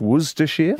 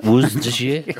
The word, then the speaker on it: Worcestershire: The gin dog says that I was mispronouncing Worcestershire. Okay. I was going like a whoosh. I think it's Worcestershire. – Worcestershire.